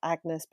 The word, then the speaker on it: Agnes: So this reimagining of Hamnet's life and the life of Shakespeare's wife, Agnes, as in spelled Agnes but pronounced Agnes,